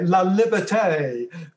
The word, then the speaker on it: La Liberté: The Time Trilogy, produced by, as you've said, Kevin Lillibert. I can't pronounce it. Sorry, Lillibert. Is that correct? – It's, yeah, Kevin Lillibert, and sounds French. – Okay, La Liberté.